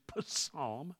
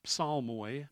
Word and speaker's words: psalm: In the Greek, you always pronounce the P. Always did. So it is psalm. Psalmoi, as it comes to us from the Septuagint of the Old Testament. But that's okay. If you call it psalm and don't pronounce the P, you're totally forgiven.